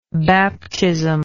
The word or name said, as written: baptismly